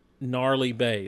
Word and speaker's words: Gnarly Bay: That's G, silent G, Gnarly Bay. The rest of it's kind of how it sounds.